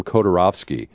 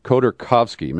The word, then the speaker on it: Khodorkovsky: His name is Khodorkovsky, and I called him Khodorovsky. Khodorkovsky, Mikhail Khodorkovsky.